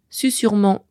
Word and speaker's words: susurrement: car il est entre deux U qui sont bien des voyelles, mais c'est un piège. Susurement comme susurré s'écrivent bien avec un seul S. Ils viennent du latin susurrus et ont conservé leur prononciation initiale.